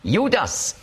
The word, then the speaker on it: Judas: Judas ist die griechische Aussprache von Judah, beziehungsweise Jehuda. Und zwar macht man eben noch ein S am Schluss, weil für griechische Ohren Judah, das klingt weiblich. Aber mit dem S ist es männlich.